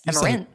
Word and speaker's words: Emirates: Emirates.